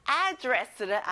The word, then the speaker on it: address: 앞에 에에 강세를 넣어보는 거예요. Address.